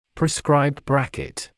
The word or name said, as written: prescribed bracket